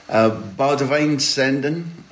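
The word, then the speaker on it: Boudewijn Zenden: Baudewijn Zenden, I hope I've done my best there for the correct Dutch pronunciation.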